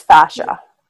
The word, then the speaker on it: fascia: It's basically the fascia. Do you pronounce that correctly? – I think it's fascia. Yeah. – Fascia. Yeah, mm-hmm.